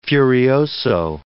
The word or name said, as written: furioso